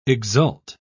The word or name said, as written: exult